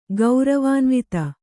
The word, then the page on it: ಗೌರವಾನ್ವಿತೆ: gauravānvite